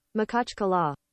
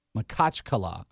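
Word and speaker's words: Makhachkala: So here's what Google says the pronunciation of the city in Russia is. – Makachkala. – Makachkala. I was so off.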